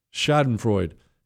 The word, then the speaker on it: Schadenfreude: Not in the Schadenfreude. Hey, how do you say that word? It's Schadenfreude.